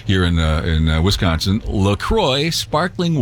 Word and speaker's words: LaCroix: See, it's a cross between La Crosse, Wisconsin, where it's made, and the St. Croix River in western Wisconsin. So it's pronounced La Croix because of that.